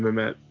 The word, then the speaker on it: Mamet: And of course, David Mamet being like a not awesome dude lately, but great writer though.